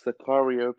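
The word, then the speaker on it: Tsakario: It's pronounced Sakario Plays on YouTube. So that's T S A K A R I O.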